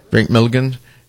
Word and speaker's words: Drake Milligan: So if you want to go to a concert with Drake McMillan, am I saying that right? – Drake Milligan, Michelle Marie, and Porter Martin.